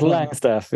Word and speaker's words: Longstaff: How would you pronounce it? Is it Langstaff? – It's Langstaff, yeah.